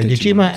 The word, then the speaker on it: Dejima: Dejima? – Fine. – Okay, Dejima and Nagasaki.